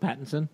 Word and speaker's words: Pattinson: Pattinson? Is that how you say it? – Pattinson.